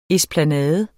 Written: ESPLANADEN

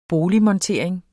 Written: boligmontering

